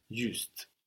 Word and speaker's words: juste: Juste. So spelled just like the English, but with an E on the end to let us know to pronounce that final consonant. Juste.